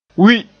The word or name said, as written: wi